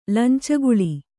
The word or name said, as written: ಲಂಚಗುಳಿ